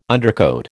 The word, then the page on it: undercoat: American English